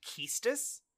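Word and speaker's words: Quistis: Squall is 17, and I hear her name lately most often pronounced Keistis. – Keistis, okay. – But I don't know if that's correct.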